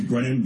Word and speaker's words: Brennan: Last name is Brennan, B-R-E-N-N-A-N.